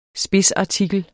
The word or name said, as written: SPIDSARTIKLERNES